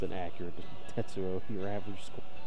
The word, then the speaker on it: Tetsuo: You have to say it like the soundtrack is a Tetsuo.